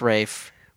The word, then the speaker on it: Ralph: We've been told it's pronounced Rafe, which I still believe is like a Charlie Kaufman-esque.